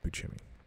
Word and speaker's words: Buscemi: Yeah, it's pronounced Buscemi.